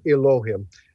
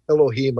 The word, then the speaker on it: Elohim: You might hear it pronounced Elohim. Elohim, I think, is closer to the right Hebrew pronunciation.